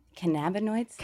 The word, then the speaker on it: cannabinoids: And then also this doctoral degree that focuses on, say it again, Cannabinoids. – It's the emphasis on that middle syllable that I struggle with.